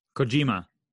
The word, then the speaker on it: Kojima: Kojima. – Help me out. I can't do it. – Satoshi Kojima. – Kojima? Is that how you say it? – Kojima.